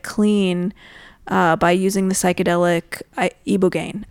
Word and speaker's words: ibogaine: Ibogaine? How do you ibogaine. – Pronounce it? Ibogaine. – As far as I know. Although I've heard ibogaine, but the people we've spoken with, at least for the upcoming episodes, say ibogaine.